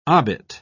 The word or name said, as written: obit